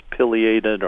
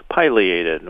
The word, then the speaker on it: pileated: Now, pileated can be correctly pronounced pileated or pileated, whichever, it doesn't matter.